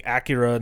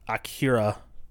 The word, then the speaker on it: Akira: I'm already saying Akira, not Akira. Even though in the movie, in the dub, both dubs, I think, they say Akira.